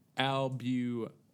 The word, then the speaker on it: albumen: A-L-B-U. N-I-N. – Here, let me pronounce that word again. Albumen. – Albumen. What about Albuman? – Let me pronounce that again.